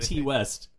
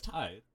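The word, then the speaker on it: Ti: They said T West. – Interesting. – Yeah, I was like, are we saying it wrong? We're not saying it wrong. – It's, no, it's Ty. Yeah.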